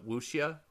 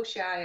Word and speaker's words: wuxia: Both are more like a, a what's the how do you pronounce it? I always fuck it up. Uh, wuxia, wuxia. – Oh, wuxia, yeah.